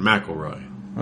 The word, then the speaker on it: McElroy: McElroy. Okay. – All right. Yeah. We got confirmation. McElroy. – Okay. – That's how you say it.